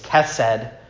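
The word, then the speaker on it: chesed (חסד): The word for steadfast love is the way it's pronounced is chesed. So you might hear some theologians say that sometime. Slow to anger, abounding in chesed.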